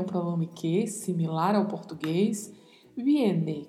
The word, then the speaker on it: viene: Viene. Lembre-se sempre, a letra V tem sempre um leve som de B. Então pronunciamos viene, viene e o verbo no infinitivo venir, com o vibrato do R ao final.